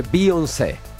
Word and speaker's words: Beyoncé: Allora, lei si scrive Beyoncé, ma si legge Beyoncé.